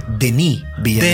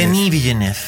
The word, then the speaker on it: Denis Villeneuve: Se pronuncia Denis Villeneuve. – Denis Villeneuve. – Porque es franco-canadiense.